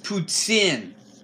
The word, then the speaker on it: poutine: Patzine is so good. – What is going on? Now you're faking Charles' lame Canadian accent and foods? It's pronounced poutine. Even I know that.